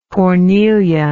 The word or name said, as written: cornelian